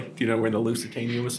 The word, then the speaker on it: Lusitania: Sinking the Lusitania.